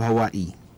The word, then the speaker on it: Hawaii: Actually, Hawaii siya. That's the way it's pronounced. Hawaii.